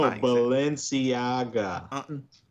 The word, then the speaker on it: Balenciaga: Balenciaga? Yeah, Jesus Christ. – that's no, Balenciaga.